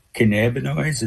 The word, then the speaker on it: cannabinoids: And so it would define hemp according to the new federal standards that would outlaw intoxicating cannabinoids, as that's how it's pronounced, such as Delta 8.